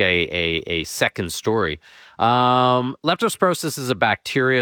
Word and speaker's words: leptospirosis: It's leptospirosis. I said that right. Yes. – Yeah, leptospirosis.